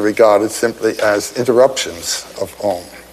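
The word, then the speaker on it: AUM: Explain om.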